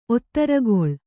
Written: ಒತ್ತರಗೊಳ್

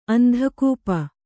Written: ಅಂಧಕೂಪ

